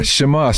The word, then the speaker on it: Shamah: Am I saying that correctly? – Yeah, Shamah.